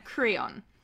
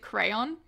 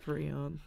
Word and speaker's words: Creon: So Oedipus has sent Creon, who they pronounce in the 50s like staging I watched, they pronounced it crayon, which I love. – Creon.